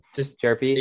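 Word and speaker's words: Jurrangelo: Jerpy. I don't know how to pronounce his name. – Jerpy. – Jerpy? – H is silent. – Jerpy.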